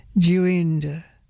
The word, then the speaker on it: duende: So here is Mark Isaacs, and from the CD Duende, and I've written down the pronunciation here, Duende. It's a Spanish noun, meaning or found in folklore.